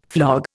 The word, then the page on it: flog: Indian